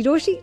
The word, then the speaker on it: Rochie: मेरा नाम है मिर्ची रोशी, नाम की स्पेलिंग है ROCHIE.